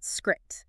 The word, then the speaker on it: Skrixx: And just for you listening, it's spelled Skrixx, but it is pronounced Skrit. So it basically rhymes with Strix.